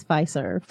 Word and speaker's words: Fiserv: She is with Fiserv. Is that how you pronounce it? – Yes, Fiserv.